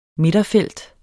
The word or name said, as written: midterfelt